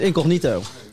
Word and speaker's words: incognito: Wie is er incognito? – Het is incognito. – Nee, is het nou incognito of incognito? – Het is incognito.